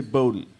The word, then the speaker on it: Boudin: Is that, before we continue, Boudin is the way to pronounce it. I've heard some people say Boudin. – Boudin is no? – Boudin is no.